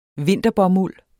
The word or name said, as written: vinterbomuld